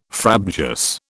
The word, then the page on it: frabjous: British English